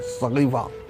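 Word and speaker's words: सक़ीफ़ा: सरकारे दो आलम के वसल मुबारक के बाद एक बांस का बना हुआ बड़ा झोपड़ा, जिसका सही प्रोनाउंसिएशन शायद सहीफा या सगीफा है, सगीफा, हाँ सगीफा, सगीफे के अंदर इलेक्शन के जरिए एक रजी अल्लाह अनु को पैगंबर का खलीफा और बादशाह इलेक्शन के जरिए चुना गया।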